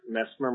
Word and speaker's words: Messmer: Hi, my name is Patrick Mesmer. My last name is spelled M-E-S-S-M-E-R.